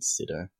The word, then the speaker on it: Sydow: It's a bit more like French in its pronunciation, but I mean, like, if you want to anglicize it, it's Sido.